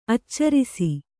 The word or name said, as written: ಆಚರಿಸು